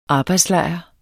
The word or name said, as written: arbejdslejr